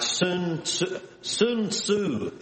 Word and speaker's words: Sun Tzu: And it was written by a man, if I say his name right, Sun Tzu is how you say it. It's not spelled the same, but it's S-U-N-T, and his last name's T-Z-O, or T-Z-U maybe, I forget.